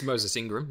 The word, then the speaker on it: Moses Ingram: And as far as the case with Moses Ingram goes, I'm sorry if I butchered the name. – No, I think it's Moses Ingram.